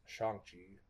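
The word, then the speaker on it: Shang-Chi: First of all, I've been saying his name wrong. It's Shang Chi.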